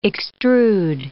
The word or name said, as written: extrude